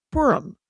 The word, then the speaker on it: Purim: Like, what about Purim? Am I saying that right? Where it's the kids? – Yes, you are.